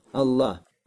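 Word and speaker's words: Аллах (ٱلله): «Аль-иля» из-за того, что часто произносится, поэтому что не «аль-иля», а «гамза» убрана для упрощения. И что? Поэтому «Аллах». «Аллах».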